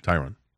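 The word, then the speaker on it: Thayron: Also getting consideration, Deron Lorenzo, Tigers. – Tyron.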